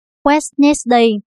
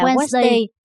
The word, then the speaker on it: Wednesday: Nếu chúng ta phát âm từ này theo đúng cách đánh vần của nó, thì chúng ta phải nói Wednesday. Nhưng thực tế, chúng ta lại phát âm là Wednesday mà không đến xỉa gì đến chữ đi.